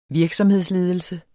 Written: virksomhedsledelse

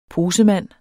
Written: posemand

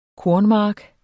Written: kornmark

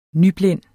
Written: nyblind

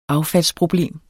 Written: affaldsproblem

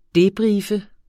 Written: debriefe

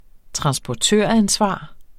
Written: transportøransvar

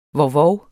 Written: vovvov

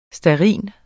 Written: stearin

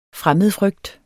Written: fremmedfrygt

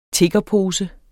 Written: tiggerpose

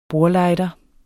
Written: bordlighter

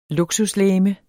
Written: luksuslegeme

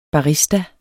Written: barista

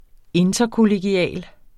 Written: interkollegial

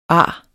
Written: ar

